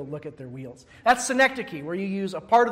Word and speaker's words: synecdoche: But Gregory would counter by pointing out that these terms, flesh, soul, spirit, often when they're speaking of mankind, they're used as synecdoche. I hope I'm pronouncing, Aaron, am I pronouncing that right? Aaron's gone. Yes, I got the thumbs up. Synecdoche, that is declaring the whole by the part.